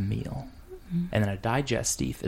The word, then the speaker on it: digestif: Like Jaeger is a digestif because that's like a little digestive. – Digestif. That's how they say it. – Digestive. – Digestif. It's how you say it.